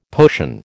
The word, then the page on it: potion: American English